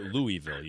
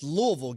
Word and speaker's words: Louisville: He also called it Louisville. You hear that? – Yeah, it's Louisville. Get it Louisville. Right. That's why I know you went to damn SC. Can't even say the name right.